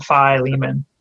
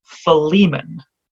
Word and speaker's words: Philemon: Philemon.